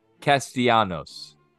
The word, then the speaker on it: Castellanos: Nick Castellanos.